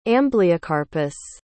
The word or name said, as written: ambleocarpus